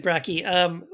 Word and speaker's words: Brakke: And your last name is Brakke, right? Is that how you pronounce it, or is it, how do I pronounce it? – That's correct. Yep. – David Brakke.